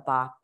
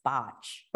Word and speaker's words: Bach: And technically, I know we all call it Bach, but it's, yeah. – it's apparently pronounced botch.